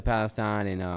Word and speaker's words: Palestine: Right, and so true prayers up to Palestine,